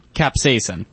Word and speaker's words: capsaicin: Capsaicin.